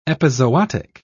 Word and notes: epizootic: British English

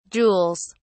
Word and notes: jewels: British English

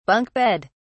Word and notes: bunk bed: British English

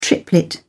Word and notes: triplet: British English

